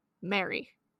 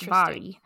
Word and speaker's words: Mhairi: I have one of her books, and I've been saying Mary. Or Mhairi or something like that. – So, yeah. So it's Scottish Gaelic form of the name, so it's actually pronounced Mhairi.